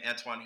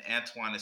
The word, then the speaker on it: Antwon: You can also find me on Facebook at AntoineR.Harris or my regular name, Antoine Harris, both. You can find me on Instagram at coach.aharris And on Twitter, you can find me at Antoine Harris 21. And Antoine is spelled A-N-T-W-O-N.